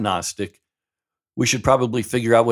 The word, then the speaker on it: Gnostic: The word breaks up with the prefix a, meaning not, and then the word Gnostic, G-N-O-S-T-I-C, the G is silent, and somehow it's not silent when you throw the A in front of it.